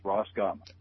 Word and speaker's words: Roskam: you know, a guy named Peter Roskam, and that's literally how his name is pronounced, Roskam.